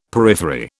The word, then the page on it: periphery: British English